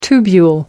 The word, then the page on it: tubule: American English